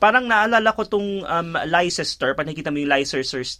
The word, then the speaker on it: Leicester: Oh, Pag yun yung pala mo siya, L-E-I-C-E-S-T-E-R, yung pala, li- so pag tingnan mo, ay Leicester.